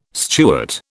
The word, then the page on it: Stuart: British English